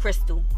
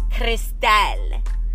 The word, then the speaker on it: Christel: And I always love Crystal. I like the way it's spelled. It's spelled C-H-R-I-S-T-E-L. Crystal, as my abuela would say.